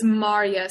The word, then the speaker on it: Maria: And this is even more like direct physical, like onto, you said the Polish pronunciation is what? Maria? – So it's Maria. So like two syllables, Maria.